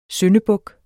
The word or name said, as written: syndebuk